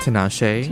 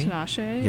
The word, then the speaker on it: Tinashe: What pronounce that? – Tinashe. – Tinashe. – Yes.